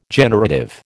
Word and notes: generative: American English

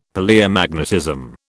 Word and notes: Palaeomagnetism: British English

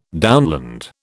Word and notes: downland: British English

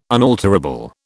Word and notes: unalterable: British English

